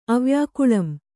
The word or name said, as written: ಅವ್ಯಾಕುಲಂ